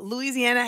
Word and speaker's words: Louisiana: Y'all, Louisiana has 5 syllables. Louisiana.